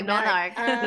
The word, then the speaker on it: menarche: I keep saying menarche, or is it menarche? And what is it? – Menarch. – Okay, menarch.